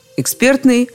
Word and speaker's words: экспертный: Слова «эксперт», «экспертный», произносится только так и никак иначе.